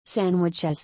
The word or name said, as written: Sandwiches